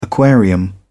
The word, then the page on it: aquarium: British English